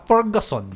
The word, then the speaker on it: Ferguson: Tama ba yung pronouncing name niya? Ferguson? How? – Rebecca Ferguson. – Ferguson, yeah. Never enough.